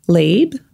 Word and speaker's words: Libre: Well, I've missed the boat with the hens and stuff like that, but I was always going to do YSL Lieb. Pretty sure that's how you say it, L-I-B-R-E.